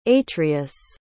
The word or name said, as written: atreus